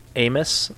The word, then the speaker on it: Amos: So it's Amos and then Habakkuk. – That's the correct pronunciation? – Yep.